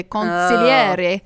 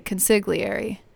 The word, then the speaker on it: consigliere: I think if you said it the Italian way, you would say consigliere.